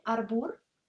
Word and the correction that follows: albur: Ah. – Albur. – Albur. Albur.